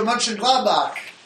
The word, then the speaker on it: Mönchengladbach: Which is Borussia Mönchengladbach.